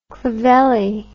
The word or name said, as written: clovelly